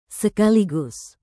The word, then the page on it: sekaligus: Indonesian